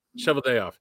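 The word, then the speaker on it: Cheveldayoff: Kevin Sheveldale. How do you say it? – Sheveldayoff.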